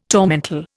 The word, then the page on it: tormentil: Indian